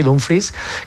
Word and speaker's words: Dumfries: Yo creo que la llegada de Dumfries, que además dice que creo que ha pedido que le llamemos así, Dumfries, casi como se escribe prácticamente,